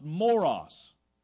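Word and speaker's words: moros (μωρός): the Greek word that is pronounced moros. Does that have a familiar sound to you? Reminds you of anything? It is the root. It is the linguistic root for our word, common word, moron today.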